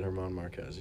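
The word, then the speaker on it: Germán Márquez: In San Diego, the Rockies beat the Padres 7-2 behind a strong performance from German Marquez. I probably butchered his name. – You're good, German Marquez. You got it. It's a tough one.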